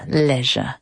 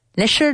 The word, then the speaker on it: leisure: Pronounce it correctly. Go for it. – Leisure, no. Leisure. – Ya estamos. No, lo he oído igual que lo que yo he dicho. – It's not even the pronunciation section. – Leisure. – Leisure. More vibrations. – Leisure. – Teeth together.